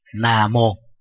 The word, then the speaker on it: Nam Mô: Nam Mô đó là một thuật ngữ được phiên âm từ tiếng Pali và Sanskrit, Nam Mô. Vì người Trung Quốc có một số từ họ không phát âm chuẩn như là người Việt Nam, cho nên họ viết chữ Nam Vô. Mà chữ Nam á, cuối cùng nó là M.